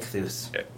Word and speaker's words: Ichthus: Ichthus, is that how you say it? – Ichthus. – Very well done.